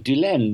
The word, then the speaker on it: Dulaine: And Pierre Heaney, which is spelled H-E-N-E-Y, just did not sound correct. So a friend of mine and myself, sorted out, made the name of Dulaine, making it two syllables, like Pierre Dulaine, that the English people can pronounce when they see it. And if they hear it, they can write it.